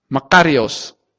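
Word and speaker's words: makarios (μακάριος): I think I'm pronouncing it right. Is it makarios? Makarios. It's makarios.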